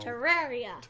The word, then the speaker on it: Terraria: Terraria. – Terraria. – Terraria. Say it like that. – Terraria. – Terraria. – Terra. – Terraria. – You know what Terra is? Terra is like Earth.